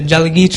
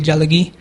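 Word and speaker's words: Jalagee (ᏣᎳᎩ): I'm from the Jalagee tribe. It's Cherokee. That's just how you say it in Cherokee, Jalagee.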